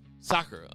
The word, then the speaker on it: Sakura: How do you say the name? – Sakura.